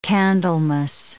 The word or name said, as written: Candlemas